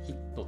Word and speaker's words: HIIT: 皆さん知ってますかねこれ大体話すと知らない方が多いんですけれども HIT ってやつなんですよね HIIT って書いて HIT って読む